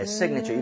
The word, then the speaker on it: signature: 그래서 앞쪽 I의 강세를 좀 주면서 사실은 signature가 살짝 나오지만 실제로는 발음을 빠르게 들으면 거의 signature. 거의 A 발음이 거의 안 나오고.